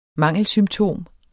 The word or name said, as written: mangelsymptom